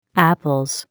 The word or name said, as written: Apples